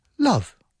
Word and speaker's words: love: Because it's of course, it looks like it should be pronounced lobe. But it's not. It's love. Una palabra tan sencilla. Love. L-O-V-E.